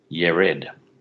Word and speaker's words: Jared: So if you're reading Genesis 5 and you want to say Jared, that's fine, but the Israelite pronunciation would be more like Yared.